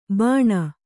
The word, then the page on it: ಬಾನ: Kannada